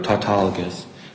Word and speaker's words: tautologous: Circular, tautologous. – I'm sorry, what word are you using? It sounds like you're saying tautologous. – Tautologous, T-A-U-T-O-L-O-G-O. – You're getting softer. – Tautologous. I apologize if I'm mispronouncing.